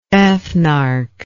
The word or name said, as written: ethnarch